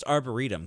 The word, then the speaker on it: arboretum: Apparently, it's just me and Dan because, like, I was at work and my coworker was like, "Oh yeah, that's pronounced Arboretum," because I guess I just swung for the fences and was terribly wrong.